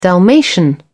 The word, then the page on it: dalmatian: British English